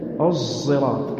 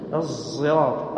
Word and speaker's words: الصراط: لأنها أولا مطبقة مهموسة، كثير من يقول يجهرها كالزاي، هي مهموسة لأن غالب الصفات صفات الصاد، تنطق زايا بصفات الصاد مهموسة الصراط الصراط، وليس مجهورة كالزاي، ومطبقة ومستعلية لتكون صفاتها الغالبة زايا صادا عفوا، لهذا قالوا قريبة للصاد.